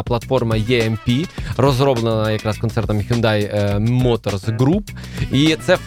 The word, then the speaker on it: Hyundai: Давай для початку будемо правильно говорити Hyundai. – Hyundai, Genesis.